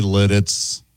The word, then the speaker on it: Lititz: L-I-T-I-T-Z. Lititz. – That's absolutely Lititz.